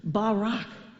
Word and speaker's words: Barak: Barak has like a really guttural sound at the end of it if you were really saying it in Hebrew. Barak.